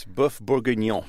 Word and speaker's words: boeuf bourguignon: How do you say it? – It's boeuf bourguignon.